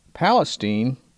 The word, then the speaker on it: Palestine: And let's not forget Palestine, pronounced with a long E in these parts, though certainly not in the Middle East.